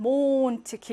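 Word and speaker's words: Mond: 엔데 두 개 나오지만 몬트 길게. 베에스테 세 개 나오지만 옵스트.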